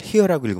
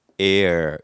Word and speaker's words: heir: H-E-I-R. 이것은 뜻이 상속인이란 뜻입니다. 상속자. 이것은 뜻이 피붙이라는 뜻입니다. 물론 읽을 때 이것을 hear라고 읽으면 안 돼요. 왜냐? 시간을 hour 할 때 h를 안 읽는 것처럼 h는 묵음이에요. 그래서 읽을 때는 air 이렇게 읽어줘야만 해요.